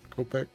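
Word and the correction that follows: khopesh: Kopesh, I believe. – Kopesh. Thank you.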